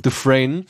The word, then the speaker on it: Dufresne: wie wird das ausgesprochen? – Dufresne, gibt und da gibt es effektiv gibt's da nichts zu meckern.